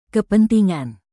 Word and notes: kepentingan: indonesian

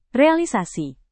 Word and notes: realisasi: Indonesian

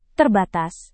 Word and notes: terbatas: indonesian

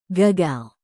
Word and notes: gagal: Indonesian

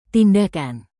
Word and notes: tindakan: Indonesian

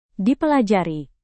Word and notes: dipelajari: Indonesian

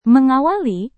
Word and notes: mengawali: Indonesian

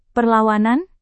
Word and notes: perlawanan: Indonesian

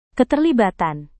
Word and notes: keterlibatan: indonesian

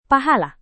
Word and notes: pahala: Indonesian

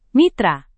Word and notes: mitra: Indonesian

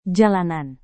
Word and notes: jalanan: Indonesian

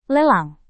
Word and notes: lelang: indonesian

